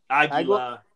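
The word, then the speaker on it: Águila: Next match, we got Taka Michinoku facing Aguila. Did I say that right? – Aguila. Aguila. Aguila. Yeah uh, for the light heavyweight title.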